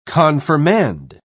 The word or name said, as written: confirmand